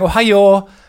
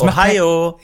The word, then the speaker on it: Ohio: Aber alle sagen Ohio. Also so, es fließt so aus. So tief, hoch, tief. – Es Ohio. Ja, genau. Mhm. Das ist anscheinend die Betonung, aber das ist ja eine ganz irrational, also dem deutschen Ohr klingt es einfach wie ein Schrei, wie falsch betont einfach sofort.